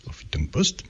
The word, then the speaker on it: Huffington Post: Huffington Post.